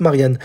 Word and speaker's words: Marian: Alors quand je dis Marianne, hein, ce n'est pas un prénom féminin, ça s'écrit M-A-R-I-A-N, sans le N-E à la fin. Mais ça se prononce Marianne.